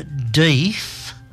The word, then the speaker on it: Death: His name was Dr. Deeth, but it was spelled Death. Dr. Death. – Oh, he just pronounced Deeth.